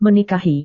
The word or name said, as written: menikahi